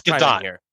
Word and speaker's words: Gadot: It's Gadot. It is Gadot. – Confirmation. Gal Gadot. People say it wrong all the time.